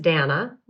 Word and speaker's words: Dana: My real name is Dana, which rhymes with banana, but is spelled like Dana.